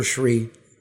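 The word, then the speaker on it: Thanedar: Yeah, I always mispronounce his name. – Olia? – It's Shree. – Oh, Shree. – Yeah. Is it Olia? – Olia. – Adam Olia and Shree.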